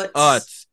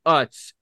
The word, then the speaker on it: Utz: It's Utz. It is Utz.